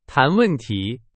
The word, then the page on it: 谈: Chinese